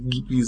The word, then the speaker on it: Ghibli: Das ist ein, ein Aussprachefehler. Eigentlich muss es tatsächlich Ghibli sein, denn es äh, richtet sich gleich nach dem Wüstenwind in Nordafrika, wenn ich das jetzt nicht verwechsel.